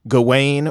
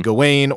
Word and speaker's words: Gawain: Although Sir Gawain, which for the pronunciation, different places, different ways. So it could be Gawain or Gawain. And then Lowry was saying, Sean Harris, who plays King Arthur in this, he said Garwin or something in the movie,